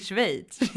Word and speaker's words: Schweiz: Och tyckte väl de var lite märkligt, men Eh, så jag åkte först eh, till Schweiz, Jätte, också jättekonstigt. Och jag kan aldrig riktigt uttala det landet. Eh, Schweiz. Schweiz, precis. Schweiz, ja.